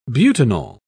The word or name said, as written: butanol